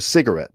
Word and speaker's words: cigarette: And in the US, you might smoke a cigarette. – Yeah, a cigarette. Yeah. So, Cigarette. – I would actually put the stress on that one in the last syllable. Cigarette.